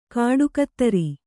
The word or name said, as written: ಖಡುಗಧಾರೆ